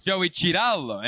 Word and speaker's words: Cirillo: Back alongside Joe Cirillo. I almost did it. Joe Cirillo. Joe Cirillo. But wait, see the Italian. The Italian, right? Joe Cirillo. But what's the Italian pronunciation? – Joey Cirillo.